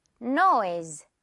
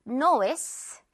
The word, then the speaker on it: noise: 이것도 역시 S 이 부분은 Z 발음으로 noise 해주면 돼요. noise. Not noise. S 아니죠. Z.